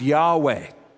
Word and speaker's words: Yahweh: It's the name we pronounce Yahweh, the ineffable name of God used by the Jewish people for God.